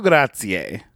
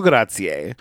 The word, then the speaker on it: grazie: Gratitudine is spelled G-R-A-T-I-T-U-D-I-N-E. Gratitudine. Gratitudine. And grazie is G-R-A-Z-I-E. Grazie. Thank you.